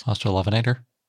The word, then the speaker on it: Australovenator: Australovenator? – Depending who you ask. Yeah, that's how you pronounce it.